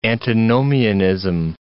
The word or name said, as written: antinomianism